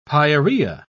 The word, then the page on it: pyorrhoea: British English